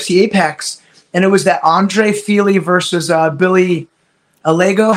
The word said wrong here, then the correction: Algeo: How do you say it? Bill Algeo. – Algeo.